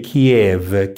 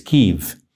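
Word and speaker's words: Kyiv: By the way, we shouldn't say Kiev. Kiev is the Russian pronunciation. It's Kiev. He was descendant of the princes of Kiev.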